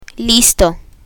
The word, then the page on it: listo: spanish